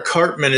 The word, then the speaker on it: Karpman: Laura Karpman is the composer for the What If series, and they are releasing episode soundtracks on Spotify.